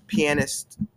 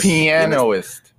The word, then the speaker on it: pianist: No, it's pianist. – Because it's spelled P-I-A-N-I-S-T. – It's pianist. – Yes, that's how it is.